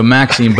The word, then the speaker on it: Maxim: But so Maxime, but it's spelled Maxim, like a fundamental principle or truth, you know, which I think is pretty cool.